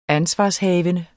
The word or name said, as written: ansvarshavende